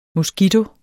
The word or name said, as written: moskito